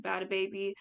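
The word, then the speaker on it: Bhad Bhabie: He was also with Bad Habit Baby. I just pronounced it that way just because it has an H. I know it's actually pronounced Bad Baby, or her actual name, Daniel Bagoli, or you may know her as the Catch Me Outside girl from Dr. Phil.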